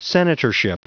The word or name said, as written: senatorship